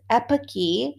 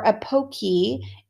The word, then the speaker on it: epoche: Husserl called this epoche or epoche. It's spelled E-P-O-C-H-E.